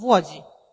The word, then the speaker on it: Łodzi: Żyła w Łodzi. – That's how you say it. Yeah. – And tak się mówi po polsku. – This is the right way to say it, yes.